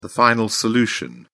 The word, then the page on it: final solution: British English